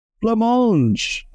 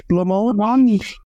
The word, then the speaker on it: Blancmange: Blancmange. Am I saying that right? Blancmange? Blancmange. Like the pudding.